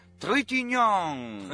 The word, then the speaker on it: Trintignant: Proseguiamo con un attore francese, era il 1930, l'11 dicembre nasceva Jean-Louis Trintignant. – Trintignant, ho apprezzato la sua pronuncia.